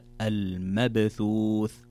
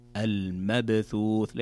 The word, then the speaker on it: المبثوث: طيب كذلك قال الله تعالى في قوله كالفراش المبثوث، هنا عندنا باء ساكنة وتكلمنا عنها سابقا أن الباء الساكنة إذا جاءت في وسط أو نهاية الكلمة يكون الحكم فيها قلقلة، والقلقلة هي اضطراب الصوت في نطق الحرف. فتقرأ المبثوث المبثوث، ليست المبثوث المبثوث.